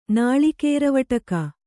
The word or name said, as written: ನಾಳಿಕೇರವಟಕ